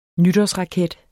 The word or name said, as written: nytårsraket